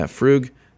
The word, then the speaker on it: Frug: Okay, yeah, Frug, I am saying it right.